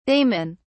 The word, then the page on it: daman: British English